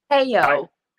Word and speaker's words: HAYO: Like, if they had any questions, if they'd like to donate to or contribute to how you say it, Heyo? – Heyo. – Heyo. Okay, I didn't want to say it wrong. I know it's H-A-Y-O.